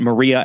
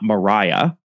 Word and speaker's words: Maria: And I'm pronouncing it Maria and not Mariah, because Tom Addison's wife was actually from Spain.